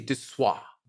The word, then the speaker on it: Desoi: I called it Dissois, which was totally embarrassing on my part. It's spelled D-E-S-O-I, so maybe I could be forgiven for calling it or pronouncing it Dissois, but... – You were absolutely. – It's actually Dissois.